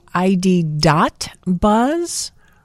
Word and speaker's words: ID. Buzz: Isn't it the ID. Buzz? – Don't pronounce the dot. Yeah, but it is ID. Buzz. That's how it's written.